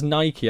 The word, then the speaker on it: Nike: I mean, since I've moved down south, everyone says Nike, and I think that sounds ridiculous. But apparently, that is how it's said. But I mean, I read it and I see Nike.